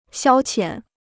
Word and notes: 消遣: xiāo qiǎn

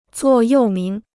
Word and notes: 座右铭: zuò yòu míng

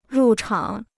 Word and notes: 入场: rù chǎng

